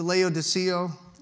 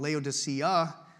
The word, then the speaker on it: Laodicea: We've been to Laodicea. You can see, I keep saying it wrong, Laodicea.